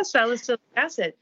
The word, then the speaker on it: salicylic acid: And what about, I can never say this right, but salicylic acid? – Yeah, salicylic acid.